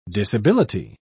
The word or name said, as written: disability